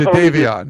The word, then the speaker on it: Jadeveon: I like the way you just Jadeveon.